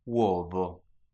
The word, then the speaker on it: uovo: Uovo. – That's three syllables.